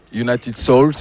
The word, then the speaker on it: Souls: United Souls, donc ça se prononce Souls, mais en français on dit United Soul.